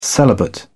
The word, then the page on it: celibate: British English